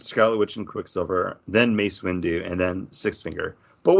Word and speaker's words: Mace Windu: Mace Windu. – Why do you keep on putting an L in there? It's Mace Windu.